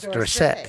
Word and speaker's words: Dorsett: Is that how you pronounce it? Tony Dorsett. That's Dorsett. Yeah.